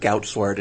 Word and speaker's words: Goudsward: Well, the proper pronunciation would actually involve spitting on the microphone because it's true Dutch. So we'll just go with Goudsward and let it go with that.